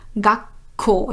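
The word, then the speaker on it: Gakko (学校): Und weil bei Gakko eigentlich Gakuko heißen würde, aber hier zwei harte Laute, zwei stummlose Laute aufeinandertreffen, wird aus dem Gaku, aus dem Ku ein kleines Zu und das verstärkt das nächste K vom Ko. Gakko, also wie ein Doppel-K.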